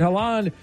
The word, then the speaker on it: Haaland: Story number two, a rising star, Erling Haaland, am I saying that right, of Norway, has been the breakout star Holland.